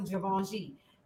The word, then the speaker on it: Givenchy: Did you hear me pronounce that right? Now I can pronounce them Givenchy.